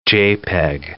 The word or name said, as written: JPEG